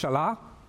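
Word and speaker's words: shalah (שלח): It's a Hebrew word which is pronounced shalah.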